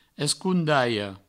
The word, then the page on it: escondalha: provençal; escoundaio